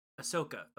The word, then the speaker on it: Ahsoka: Asuka. Is that how you pronounce her name? Asuka? – Ahsoka. – Okay, Ahsoka.